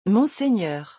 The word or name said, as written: monseigneur